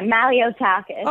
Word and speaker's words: Malliotakis: Well, let's talk about New York City's lone Republican member, Congresswoman-elect Nicole Malliotakis. Did I pronounce her name correctly? I probably did. – Yeah, Malliotakis.